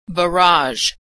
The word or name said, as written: barage